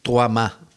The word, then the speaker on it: 3MA: Que en verdad se pronuncia como Troama.